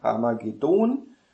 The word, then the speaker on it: Harmagedon: Harmagedon wird in der letzten Silbe betont. Ich sage mal so, weil es auf Hebräisch so ist. Und auf Portugiesisch ist es auch so. Und deswegen muss es auf Deutsch auch so sein.